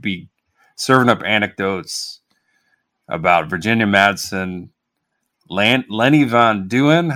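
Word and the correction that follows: Von Dohlen: Lenny Van Dolan.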